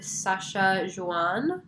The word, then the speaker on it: Sachajuan: So it's from a brand called Sasha Juan. It's spelled S-A-C-H-A-J-U-A-N.